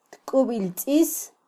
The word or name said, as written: tkubiltzis